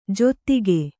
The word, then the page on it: ಜೊತ್ತಿಗೆ: jottige